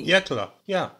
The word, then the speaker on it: Yecla: So 2017, the region, if you can pronounce this better than me, please chime in, is Yecla, Y-E... – Yecla, yeah, you got it, Yecla.